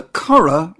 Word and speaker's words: Curragh: The Curra, which is spelled C-U-R-R-A-G-H, is a flatland south of Dublin in County Kildare.